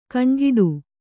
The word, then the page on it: ಕಣ್ಗಿಡಿ: kaṇgiḍi